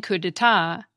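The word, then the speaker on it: coup d'état: The same goes for the silent P and silent T in coup d'etat, and the silent D and silent X in Grand Prix.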